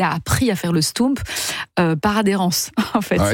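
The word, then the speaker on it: stoemp: Ça s'appelle un stoump. Et ça s'écrit S-T-O-E, parce que O-E en belge, ça fait où ? – M-P.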